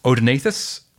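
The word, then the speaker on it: Odaenathus: Yeah, so Callistus came with two others, Odenathus, am I saying that right? – Yeah.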